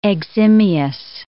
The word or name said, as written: eximious